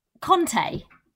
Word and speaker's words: Conte: What about Kante? How right, do you pronounce so... – Kante? – Conte.